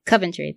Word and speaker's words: Coventry: So at the pregame press conference, we learned that Richmond is about to play Coventry City. I think is how you say it. – Coventry.